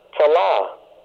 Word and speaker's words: tela'ah: Strong's Concordance 50-04, from the Greek word talaporia, which is spelled T-A-L-A-I-P-O-R-I-A, meaning hard work, hardship, distress. And Strong's Concordance 85:13, from the Hebrew word telah, which is spelled T-E-L-A-A-H, meaning weariness, hardship.